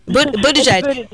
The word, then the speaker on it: Buttigieg: Buttigieg? Am I correct, Jeannie? – Yes. – Buttigieg.